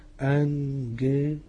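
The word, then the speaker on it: أنجيلوس (ἄγγελος): الجمتين ورا بعض الأولانية بتتنطق نون والتانية بتتنطق جه عادية، أنجيلوس.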